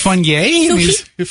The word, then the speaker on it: Funnye: Well, how do you pronounce this guy's name? Funnye. It's got to be funny, right? – It's Funnye. French.